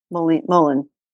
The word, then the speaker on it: mullein: Do you pronounce it Mullein or do you pronounce it Mullen? I hear it pronounced a bunch of different ways. It's Verbascum thapsus. – I would pronounce it Mullen. Mullen.